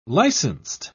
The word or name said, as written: licensed